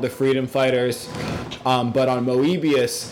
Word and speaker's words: Moebius: Some people pronounce it Moebius, because whereas normal Mobius is spelled M-O-B-I-U-S, evil Moebius is spelled M-O-E-B-I-U-S.